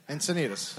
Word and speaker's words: Encinitas: So insecure about if you said it right. – Encinitas. – Okay. – There you go.